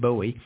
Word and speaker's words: Bowie: That's how it's pronounced in England, or for us Americans, David Bowie.